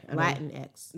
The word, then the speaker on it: Latinx: Am I saying that correctly? Latinx. – okay. Latinx.